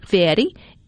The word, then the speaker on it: Fieri: American restaurateur Guy Fieri, am I saying that right? – Fieri is bringing Flavortown to Mississippi.